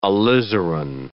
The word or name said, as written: alizarin